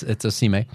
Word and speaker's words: Osimhen: I found it his Osimhen. That's how you say it.